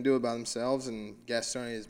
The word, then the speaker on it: Gastonia: Gastonia, North Carolina, pretty hard.